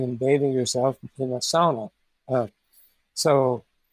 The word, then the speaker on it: sauna: Um, I think mainly Finland is the origin of the sauna. They pronounce it sauna. We say sauna, but same thing.